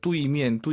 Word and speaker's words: 对面: UI 사이에 에너야죠. 뚜이미엔, 뚜이미엔. 선생님, 알아듣죠? – 能听懂. – 네, 안 예뻐요. 다시 한번. – 对面. – 뚜에이, 뚜이미엔. 빠르게 발음하시면 뚜이로 들리긴 하죠. 뚜에이, 정확히 발음하셔야 됩니다.